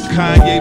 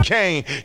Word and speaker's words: Kanye: Now all I need is y'all to pronounce my name is Kanye, but some of my plastics still say Kane.